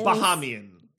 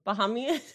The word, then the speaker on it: Bahamian: I think it's Bahamanian. – Bahamanian. – Bahamian. – Bahamian.